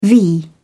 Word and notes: v: British English

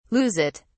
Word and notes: lose it: British English

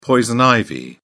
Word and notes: poison ivy: British English

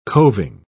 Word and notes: coving: British English

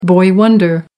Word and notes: boy wonder: American English

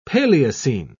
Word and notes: paleocene: British English

